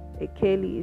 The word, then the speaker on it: Ekeli: Akaeli is spelled E-K-E-L-I.